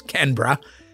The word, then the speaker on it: Canberra: Australia's capital, here it is again, is Canberra.